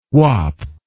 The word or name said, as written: wap